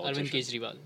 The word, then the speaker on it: Arvind Kejriwal: Arvind Kejriwal.